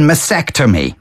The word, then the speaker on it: mastectomy: Please pronounce the T in mastectomy. – Mastectomy. And yeah. Even to spell it is M-A-S-T. – Yeah, E-C-T.